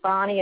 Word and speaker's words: Bonnie: And you can reach me on my Facebook at Bonnie Mosenter Albers. So I'll spell it for you. Bonnie is B-O-N-N-I-E. Mosenter is M-O-Z-E-N-T-E-R. And my last name, Albers, A-L-B-E-R-S.